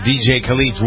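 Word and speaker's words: DJ Khaled: This is DJ Khalid's Wild Thoughts, featuring Rihanna and Bryson Tiller.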